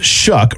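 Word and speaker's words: Shough: Tyler Shuck, it is Shuck, by the way, pronunciation. It says in the article, it says the last name is pronounced Shuck, rhyming with words like buck or shucking corn.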